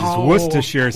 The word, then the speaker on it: Worcestershire: Hey, I think the most unpronounceable word is that Worcestershire sauce. – It is Worcestershire sauce.